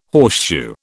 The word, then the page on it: horseshoe: British English